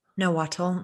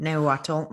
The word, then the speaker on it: Nahuatl: He worked at the Mexico City College, and his area of expertise was like Aztec culture and the Nahuatl. Am I saying that right? Nahuatl language? – Nahuatl.